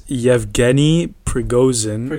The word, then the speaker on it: Prigozhin: How do you say this guy's name? – Okay, his name is Yevgeny Prigozhin.